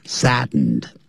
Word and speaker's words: sadden: 那时候你就要用动词 saddened, 后头有 by 嘛。所以呢你就可以这样说 The story saddened me. 要念成 sadden, 用鼻音出来。Be saddened, saddened me, and I was saddened by the story.